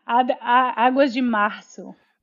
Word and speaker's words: Águas de Março: Águas de Marco. How do you say that? – Yeah. Águas de Março. – Março. Yeah, that's right.